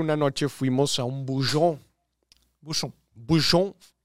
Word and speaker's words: bouchon: Un bouchon es un... – Bouchon.